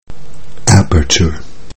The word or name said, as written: aperture